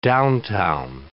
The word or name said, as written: downtown